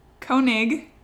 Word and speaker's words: Koenig: Um, on the first season of Serial, uh, it, which was hosted by Sarah Koning. Koenig, ah, Koning. I can't say her name, ah, Koenig, there it is.